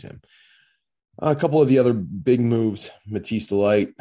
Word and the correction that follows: Matthijs de Ligt: Uh, I'll pronounce that correctly this time. Matthijs de Ligt.